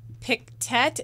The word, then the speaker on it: Pictet: Also, Nathan writes that Pictet is pronounced Picte. I have no idea what that is, but I believe him that I mispronounced it.